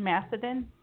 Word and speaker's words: Macedon: I was stalking you when we were, you know, having you on the show, and it said that you were you live in Macedon, New York. Is that correct? – Yeah, and you know how to pronounce it right.